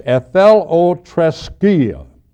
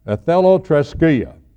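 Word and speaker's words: ethelotreskia (ἐθελοθρησκία): The Greek word's kind of hard to pronounce, but it's ethelotreskia. Ethelotreskia, ethelotreskia, a long Greek word.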